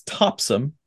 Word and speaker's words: Topsham: Like there was a there was a town called T O P S H A M. And I went for weeks saying this name wrong until I was corrected it's Topsom.